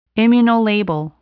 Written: immunolabel

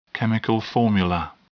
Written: chemical formula

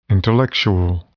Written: intellectual

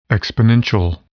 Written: exponential

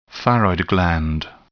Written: thyroid gland

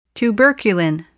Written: tuberculin

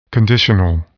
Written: conditional

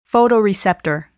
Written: photoreceptor

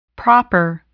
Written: proper

